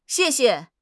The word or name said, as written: xie4 xie5